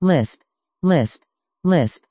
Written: lisp

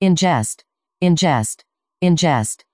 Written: Inngest

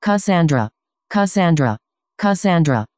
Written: cassandra

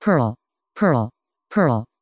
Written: perl

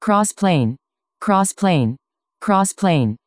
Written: crossplane

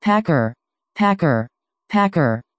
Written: packer

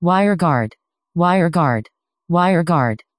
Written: wireguard

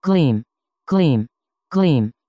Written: gleam

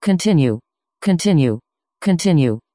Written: continue

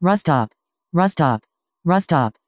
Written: rustup

